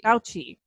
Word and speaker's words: Fauci: You actually said this name earlier, which makes me think I'm pronouncing it wrong, but, you know, Dr. Anthony Fauci. – Fauci.